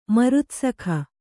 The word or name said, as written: ಮರುತ್ಸಖ